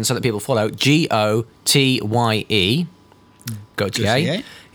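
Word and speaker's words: Gaultier: So, yes, it's pronounced Gautier, as in Jean-Paul. – I always called it Goit.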